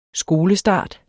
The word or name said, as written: skolestarter